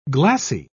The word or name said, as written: glassy